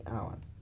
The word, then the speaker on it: hours: Si hours wa kuni hours. The H is silent.